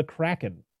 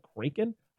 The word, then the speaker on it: Kraken: Gibbs tells the tale of the Kraken. So I guess the actual pronunciation of Kraken or of the Kraken is Kraken, but Gibbs' actor. What's his name again? Kevin McNally. He pronounced it Kraken because he is the first person to actually say it in the movie.